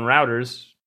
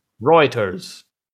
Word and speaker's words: Reuters: I have this article up from like 40 minutes ago on Routers. Reuters? How do you say that? – Reuters.